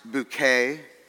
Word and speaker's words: Bucket: The antics of Richard and Hyacinth Bucket, pronounced bouquet, if you please.